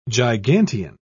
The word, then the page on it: gigantean: British English